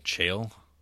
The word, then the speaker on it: Cheel: Like Chill? C-H-E-E-L. – Okay. – I don't know how you say it, but Jay Chill? – Chill.